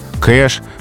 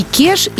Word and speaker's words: кеш: Поэтому флэш, хэш, кэш — все это в словарях фиксируется с буквы «е». – Мы mm-hmm. произносим твердо, а пишем «е», как в слове «портер», например. – И кеш, и бег, да? – пишем так, а произносим, конечно, твердо.